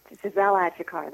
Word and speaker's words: Giselle Achecar: Well pronounced. Giselle Achikar. That's me.